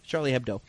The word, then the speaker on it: Charlie Hebdo: And, of course, Charlie Hebdo. Did I say that right? – Yeah. – Charlie Hebdo, of course, was the magazine that was printing all these controversial cartoons, making fun of all religions.